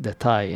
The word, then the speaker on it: detay: One is titled All That, and the other one is the title song, detail, pronounced detay in Turkish, by the way, from French, I guess.